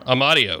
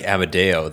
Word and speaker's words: Amadio: While you're looking, I think his last name is Amadio. – Amadio, excuse me. Last day, I can pronounce it properly now. – Although I do like Amadeo.